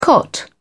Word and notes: cot: British English

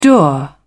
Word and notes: dour: British English

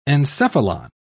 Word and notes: encephalon: British English

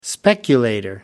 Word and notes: speculator: American English

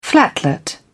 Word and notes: flatlet: British English

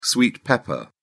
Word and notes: sweet pepper: British English

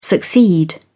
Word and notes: succeed: British English

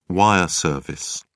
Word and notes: wire service: British English

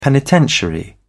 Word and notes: penitentiary: British English

